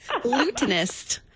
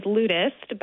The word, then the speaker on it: lutenist: Lutenist. – Yes. Lutenist. So I thought it was lutist, but I that would have been my guess. – Sure I said it correctly. And it's not. It's lutenist.